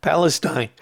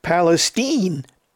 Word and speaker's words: Palestine: Although I'm tempted to call it Palestine. It's spelled Palestine, but everybody's calling it Palestine.